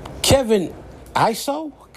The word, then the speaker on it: Kevin Iso: Kevin Iso, that's how you say his name, acted his ass off on this episode of Flatbush Misdemeanors.